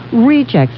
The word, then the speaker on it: Rejex: And then they have a product called Rejects, and that is spelled R-E-J-E-X.